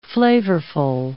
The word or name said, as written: flavorful